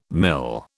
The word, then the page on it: mil: British English